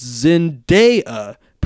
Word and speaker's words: Zendaya: Side note, it's Zendaya, pronounced like it's spelled.